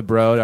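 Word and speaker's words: Broad: It's pronounced the Brode. – Oh, God. I've never wanted to choke anybody more than when the lady at the Brode was like, it's the Brode.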